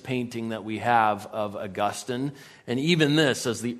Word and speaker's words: Augustine: All right, I want to start with Augustine, or sometimes it's pronounced Augustine, especially if you're from Florida. But Augustine is a more correct pronunciation of his name, though either one is fine.